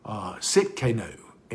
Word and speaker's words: Tsidkenu: It's spelled T-S-I-D-K-E-N-U, Sitkenu. And the T is actually silent, or it makes a T, S makes almost a Z sound.